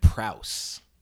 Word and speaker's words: Prowse: David Prowse. – Prowse. Yeah, I almost said Pratt. – Yeah. It's Chris but Pratt's long. – Does he spell it P-R-A-U-S-S? – No, P-R-O-W-S-E.